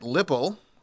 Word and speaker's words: Lipple: Lieutenant Kent Lipple, rhymes with nipple, says dispatchers received several calls from people saying they'd seen him.